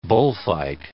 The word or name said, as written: bullfight